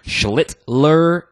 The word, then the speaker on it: Schlittler: Yes, that's how you say his name. Schlittler, emphasis on the L's, so you don't get canceled.